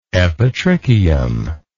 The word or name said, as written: epitrichial